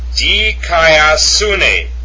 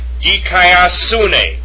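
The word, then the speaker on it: Dikaiosune (δικαιοσύνη): Dikaiosune. The accent is over the upsilon.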